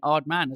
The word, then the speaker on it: Aardman: You are very welcome, and massive props for saying Aardman as well. You've said it right.